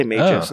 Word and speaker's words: Magus: Maybe I should maybe say Magus instead. Magus, oh, Magus. Probably because I was saying Magneto and Magma later. I guess it doesn't really matter. – You got the hard G in there.